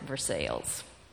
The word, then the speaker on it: Versailles: The town of Versailles had just been founded when Elizabeth and Henry came, and they do pronounce it Versailles, even though it's named after Versailles.